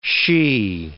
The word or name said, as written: she